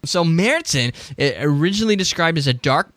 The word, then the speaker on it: Märzen: But and for those who don't know, the A is the, with the double dots is the sound of A, long A. So, and then the Z has got the TS sound. So that's why I call it Marzen.